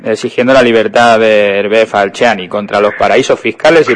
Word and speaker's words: Falciani: Pues te llamamos porque nos habéis mandado una nota de prensa por la libertad de Hervé Falciani, no sé si lo pronuncio bien. – Sí, Falciani sería.